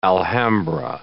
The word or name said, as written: Alhambra